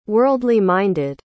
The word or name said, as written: worldly-minded